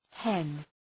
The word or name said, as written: hen